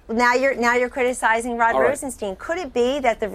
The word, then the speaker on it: Rosenstein: People can read it for himself, and his name is Rosenstein, by the way.